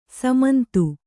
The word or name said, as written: ಸಮಂತು